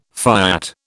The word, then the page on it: fiat: British English